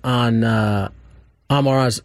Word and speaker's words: Amon-Ra: There's been a couple different things on Amon-Ra's. How do you say his first name? – Amon-Ra.